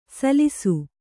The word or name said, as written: ಸಲ್ಸು